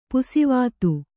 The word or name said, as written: ಪುಸಿವಾತು